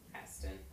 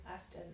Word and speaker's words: Astin: We met Jason Momoa, and we met Sean Austin. Astin. Astin. I can pronounce things correctly.